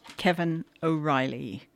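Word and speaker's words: Caoimhín Ó Raghallaigh: Mick O'Brien and an unpronounceable Irish name that turns out to be pronounced Kevin O'Reilly.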